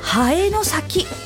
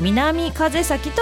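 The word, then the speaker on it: 南風崎: あやあやさん、長野県佐世保市にある南の風の、先、長崎とかの先、崎陽軒の木ね、うん、南の風の先、でハエの先と読むところが好きな難読地名ですといただきました。子供の頃はよく南風先と読み間違えました。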